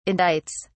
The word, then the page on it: indicts: British English